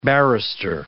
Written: barrister

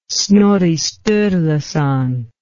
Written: Snorri Sturluson

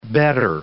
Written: bettor

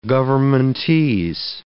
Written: governmentese

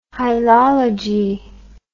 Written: hylology

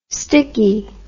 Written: stickie

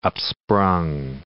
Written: upsprung

